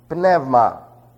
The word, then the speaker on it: pneuma: Pneuma. Remember, there are no silent letters in the Greek language, and so they're all pronounced. Like psalm, we say psalm, and we spell it P-S-A-L-M.